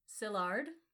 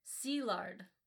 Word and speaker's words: Szilard: So he's Hungarian. And in Hungarian, an SZ, which his name starts with, is an S, as in like Franz Liszt. And an I is an E. So what is Szilard in an English pronunciation is Szilard in a Hungarian pronunciation.